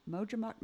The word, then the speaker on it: Majumdar: Majumdar.